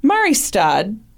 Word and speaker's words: Mariestad: Annie grew up in Mariestad, Sweden. That's exactly how it's pronounced.